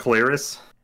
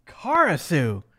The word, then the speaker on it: ClariS: It's not Clarice. – It is Karisu. Or sorry. Yeah, Karisu. K-U-R-A-R-I-S-U. – I don't know what that is. The band I'm thinking of is C-L-A-R-I-S. – Oh, no. Yeah. But what? – The Japanese pronunciation in Wikipedia says it's Karisu.